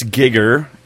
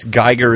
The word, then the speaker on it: Giger: It's Giger. It's Geiger is how we Americans pronounce it, but it's actually Giger, G-I-G-E-R.